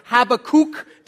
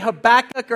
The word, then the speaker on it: Habakkuk: or Habakkuk, depending on how you say it. It's page 652. You can say it either way, Habakkuk or Habakkuk. It's actually an Akkadian loan word, so either pronunciation is fine.